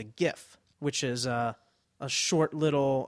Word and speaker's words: GIF: However, we've been saying GIF, but the creator of this format says it's pronounced GIF, as in the peanut butter. Right. He said it's a soft G, pronounced GIF. End of story.